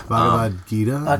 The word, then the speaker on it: Bhagavad Gita: Bhagavad Gita? – Bhagavad Gita.